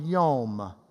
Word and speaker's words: yom: It is the word yom. Yom. Just say yom with me. Yom. We would spell it Y-O-M.